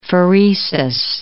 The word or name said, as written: pheresis